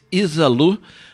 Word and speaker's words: Izalu: She's called Izalu, they don't make our life easy, but I can make it easier for you to remember the name. Her name is Louisa, right? So she just swapped some syllables around. – Syllables around, and it is Isalu.